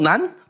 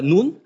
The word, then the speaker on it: Nun: Right, Nun, not Nun, but Nun. 12 Nun in that sense, that's how the pronunciation is.